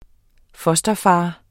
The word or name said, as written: fosterfader